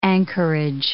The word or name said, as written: anchorage